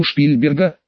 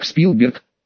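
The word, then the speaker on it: Spielberg: Знаю Шпильберга», — высокомерно ответила Шицгал. «Не ври, американцы произносят его фамилию как Спилберг», — обиженно насупилась Катя.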